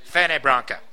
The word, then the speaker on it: Fernet Branca: He's talking about drinking what he, as Michael Caine pronounces it, Fernet Branca. It's spelled F-E-R-N-E-T space B-R-A-N-C-A.